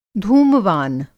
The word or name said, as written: धूमवान